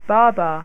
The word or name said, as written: babaa